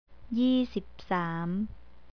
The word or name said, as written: yeesipsam